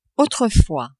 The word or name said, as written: autrefois